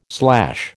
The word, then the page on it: slash: American English